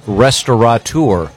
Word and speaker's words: restaurateur: I know that the most mispronounced is restaurateur. – Mm-hmm. – There's no N. It's not restaurateur.